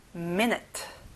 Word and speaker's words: minute: 앞에 me의 강세를 넣어보세요. minute.